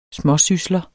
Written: småsysler

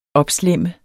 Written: opslæmme

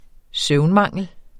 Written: søvnmangel

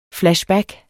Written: FLASHBACKS

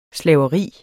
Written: slaveri

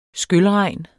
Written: skylregn

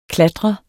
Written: klatre

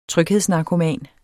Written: tryghedsnarkoman

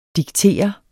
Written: diktere

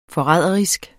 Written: forræderisk

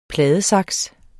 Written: pladesaks

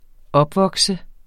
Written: opvokse

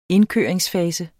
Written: indkøringsfase

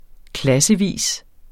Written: klassevis

